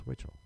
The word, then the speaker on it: Witchell: Because you couldn't, like, if you saw Witchell spelled, a lot of people don't pronounce it right. Like, Witchell? Witchell? No, it's Witchell.